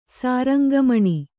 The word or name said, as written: ಸಾರಂಗಮಣಿ